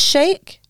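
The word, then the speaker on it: Chic: It's Sheik. – No! – Does it sound like Sheik? – Yeah, it's Sheik.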